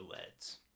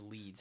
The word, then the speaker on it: Leeds: Is he, his name Leeds or Leds? It, it was Leeds.